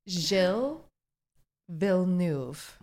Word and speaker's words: Gilles Villeneuve: Gilles Villeneuve.